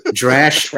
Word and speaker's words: Drash: Like one is Drash. And, okay, I must have missed that. – Drash rhymes with trash,